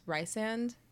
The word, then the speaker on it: Rhysand: I mean, I still just call him, it's Rhysand, but then when it's a nickname, it's Rhys. – Rhys, I don't call him Rice.